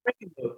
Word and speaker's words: Frankenmuth: Frankenmuth, yeah, yeah, super.